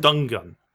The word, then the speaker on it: Dungen: I bought Dungeon, or I don't know how to pronounce their name. – Dungeon? – They're a Swedish band. – Dungen. – D-E-G-E-N.